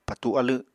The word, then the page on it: patu alw: Apatani